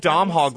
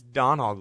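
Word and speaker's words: Domhnall: Dom Hall Gleason, it's Don Hall Gleason. There's no M pronunciation, actually.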